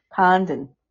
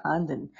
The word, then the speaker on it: Condon: Condon. It's so hard for me to say that. Condon.